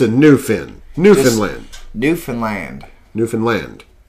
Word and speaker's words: Newfoundland: But no, the proper way to say Newfoundland is Newfoundland.